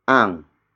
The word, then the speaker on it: ang: Before that, we have ang. Ang. Ang. Ang is spelled as A-N-G.